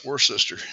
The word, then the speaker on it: Worcester: No, it's Worcester.